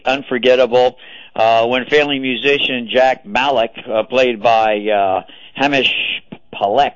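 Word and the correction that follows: Himesh Patel: I think that's how he pronounces his name, Patel, that's how it is, Hamish Patel,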